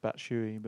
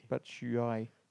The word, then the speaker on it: Batshuayi: I think it's pronounced Batshuayi, but... – Batshuayi.